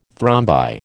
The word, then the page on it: thrombi: American English